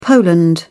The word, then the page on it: poland: British English